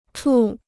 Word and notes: 处: chù

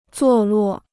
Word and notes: 坐落: zuò luò

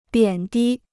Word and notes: 贬低: biǎn dī

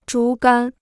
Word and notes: 竹竿: zhú gān

